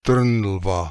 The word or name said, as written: Trnlva